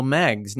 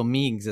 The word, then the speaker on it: Meggs: It is Michael Meggs, not Michael Meigs, as we all have been pronouncing it. – Meggs like legs. – Meggs like legs, like eggs.